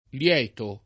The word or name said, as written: lieto